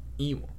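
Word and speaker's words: Ion: Let's roll on to the next fight, though, because Ian Cutelaba versus Dustin Jacoby. Ewan. My bad. Ewan. – Apparently, that's how you say it. I've been saying Ian.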